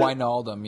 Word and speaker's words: Wijnaldum: And I've also liked that Gini Wijnaldum, or is that how you pronounce it? – Wijnaldum, yeah.